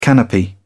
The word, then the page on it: canopy: British English